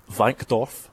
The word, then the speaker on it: Wankdorf: It's called the Stade de Suisse Wankdorf.